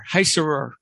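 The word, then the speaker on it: Heisserer: So then this guy, the screenwriter, and I know I'm going to pronounce his name wrong, but it's Eric Heiserer. H-E-I. H-E-I-S-S-E-R-E-R, Heiserer.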